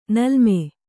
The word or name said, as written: ನೀಲಿಮೆ